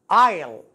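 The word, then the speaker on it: aisle: تنطق آيل الإس هنا لا ينطق آيل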